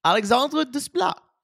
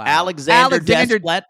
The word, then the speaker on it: Alexandre Desplat: I said it the French way. Alexandre Desplat. What? How do you say it? Wow. Alexander Desplat.